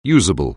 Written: usable

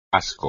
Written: asco

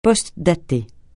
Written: postdater

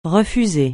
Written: refuser

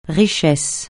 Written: richesse

